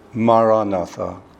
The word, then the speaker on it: Maranatha: If you choose this word, say it as four syllables Ma Ra Na Tha.